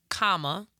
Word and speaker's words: Kamala: All right, so as she pointed out, and our babies and their baby voices pointed out, her name is pronounced Kamala, like a comma, and then la.